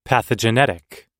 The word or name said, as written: pathogenetic